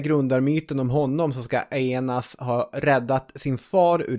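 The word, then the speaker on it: Aeneas: Men den mycket kändare grundarmyten handlar ju om Aenas. Ska vi uttala det så? – Ja, jag har kommit på något bättre sätt. Aenas.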